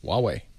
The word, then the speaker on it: Huawei: Forget the H's. Huawei. – Huawei. Ah, thank you.